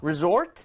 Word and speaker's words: resort: 자, 제가 방금 발음을 해드렸지만 O 쪽에다가, 그러니까 뒤쪽에다가 강세를 좀 찍으면 더 좋을 것 같아요. 여기서 resort 이렇게 하지 마시고 resort, resort 해보실게요.